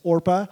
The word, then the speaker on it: Orpah: But Naomi is a good woman, and she says to her two daughters-in-law, to Orpah, which is, by the way, that's Oprah's legal name. Her mom read the name Orpah in the Bible, and people mispronounced it so many times that she started going by Oprah.